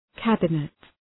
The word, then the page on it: cabinet: ‘kæbənıt